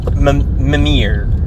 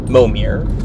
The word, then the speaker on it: Mimir: So Mimir. – I remember. Mimir. It's a different pronunciation than I'm used to. – Yes. M-I-M-I-R. – Yeah. I'm used to it with an O. Momir.